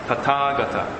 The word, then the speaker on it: Tathagata: Yeah, so here is Tathagata. Yeah, I mean that's a proper way of pronunciation.